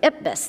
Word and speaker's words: IPBES: He also most recently chaired the Intergovernmental Policy Panel on Biodiversity Ecosystem Services, which also can be narrowed down to the acronym IPBES. Am I saying that right? – Yep. – IPBES? Okay, IPBES.